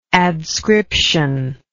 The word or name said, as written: adscription